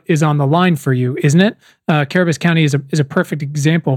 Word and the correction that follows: Cabarrus: And so I love y'all, but I'm going to tell you how to pronounce it. – Oh, please. No. Say it again. Cabarrus? – Barris.